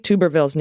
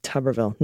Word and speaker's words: Tuberville: The tub in Tuberville isn't pronounced tube, it's pronounced tub, like a bathtub.